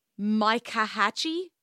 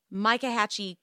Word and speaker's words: Myakkahatchee: Apparently, he took the family's Mustang and he drove nearby to, I'm going to butcher the name of this place, Micahatchee. It's MYAKKAHATCHEE. Micahatchee Creek, to go for a hike, because I guess that's what you do when the love of your life is missing.